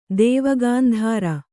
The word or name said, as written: ದೇವಗಾಂಧಾರ